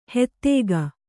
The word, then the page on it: ಹೆತ್ತೇಗ: hettēga